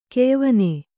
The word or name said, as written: ಕೆಯ್ವನೆ